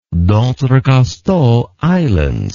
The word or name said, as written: dentrecasteaux islands